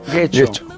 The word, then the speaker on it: Getxo: Que no, Getxo no, Getxo. – Getxo. Exacto, es donde me equivoco yo, que lo vuelvo como de...